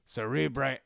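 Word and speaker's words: Cerebri: We're not even going to cover Cerebre Anatomy, which is the correct way to say it.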